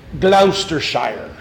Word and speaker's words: Gloucestershire: In a place called Gloucestershire. And you'd have to say it like that because it was, well, maybe more English, but it's in Gloucestershire, in southwestern England.